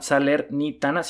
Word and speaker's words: Salernitana: el Salernitana, que segura, Salernitana, sí, sí lo dije bien, ajá.